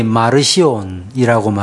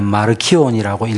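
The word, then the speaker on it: Marcion: 특별히 2세기 때 흔히 마르시온이라고 말하기도 하고 라틴어로 읽을 때는 마르키온이라고 읽기도 하는데 이 마르키온 이 정경 이들은 구약의 하나님을 부정적으로 인정했기 때문에 구약 성경을 그들의 정경으로 받지 않았습니다.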